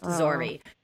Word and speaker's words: Zxory: Z-X-O-R-Y, pronounced Zori.